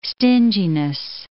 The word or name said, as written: stinginess